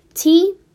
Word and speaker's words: Tatsuo: And his name, I'm gonna pronounce this wrong, but it's Tatsu Miyayama. That's Tatsuo. Here, let me go slower. Tatsuo. And now for his last name. Miyajima.